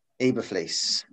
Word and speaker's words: Eberflus: Is it Matt Eberflus? – Eberflus. – Yeah.